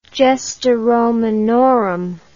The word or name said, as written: Gesta Romanorum